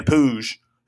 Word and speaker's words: Puig: The wonderful Jane McNeil, I heard in Mr. Pooj's press conference, said Pooj, as you say, with a Z-H, kind of a zhuzh sound.